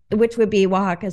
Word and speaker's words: Oaxaca: And Oaxaca is spelled like O-X-A-C-A, something crazy like that. But they spelled it phonetically W-H-A-K-A, I think. But people knew how to pronounce it. W-A, Oaxaca, whatever.